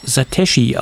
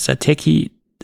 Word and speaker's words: Satechi: Und man kann es wohl auch Satechi aussprechen oder halt Satechi. Ich glaube, die beide Aussprachen ist dem Unternehmen vollkommen nicht egal, aber sie arrangieren sich mit beiden Aussagen. Und ich sage dann weiterhin Satechi, weil ich das in der Vergangenheit schon immer gesagt habe.